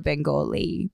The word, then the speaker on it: Bengal: Gangster, the Bay of Bengali.